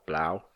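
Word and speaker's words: Blough: Allgemein wenig geworfen von David Blow. Heißt der Blow? Ja, Wird er so ausgesprochen? Blau. Blau. Blau.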